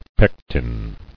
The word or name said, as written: Pectic